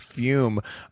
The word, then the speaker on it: Fume: That's spelled F-U-M, but it's pronounced Fume.